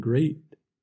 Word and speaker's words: grate: Watch out for meat, and grate, and threat. They rhyme with sweet, and straight, and debt.